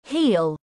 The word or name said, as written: Heal